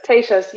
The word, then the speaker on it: Tejas: how do you help me with the pronunciation there? Is it Tasha's reading list? – Tejas. – I'm sorry. Tejas. You're right.